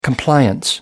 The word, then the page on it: compliance: British English